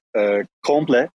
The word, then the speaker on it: Komple: So it's Komple. So Komple is in Turkish means complete or like entire. It's read as Komple.